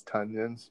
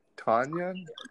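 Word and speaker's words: Tonyan: Robert Funyuns, Tunyuns. How do you pronounce his name? Tanyan? Tanyan? He was like, it's like Funyuns. – Yeah, I had said Tanya, and so I was wrong.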